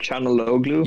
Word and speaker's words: Çalhanoğlu: Is it Çalhanoğlu? – Yes, sorry, thank you.